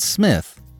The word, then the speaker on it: Psmith: we will be joining Mike and Smith, the P is silent, in their adventures in London.